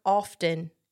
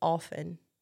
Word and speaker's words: often: It's not often, it's often. You're not supposed to pronounce the T.